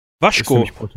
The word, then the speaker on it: Vasco: Ich, äh, wenn ich das richtig verstanden habe, spricht man den, ich habe nämlich mal äh, lange geschaut, Vasco. Waschko. ja, da Gama. Ist nämlich portugiesisch. – Ja, ach so, weil im, im Spanischen würde man Vasco sagen, ne? – Eben.